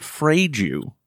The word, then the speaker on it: fragile: the fragile fragile that we all hold very tenderly in our hands. – Oh, that's interesting that you say fragile. I pronounce it frage you.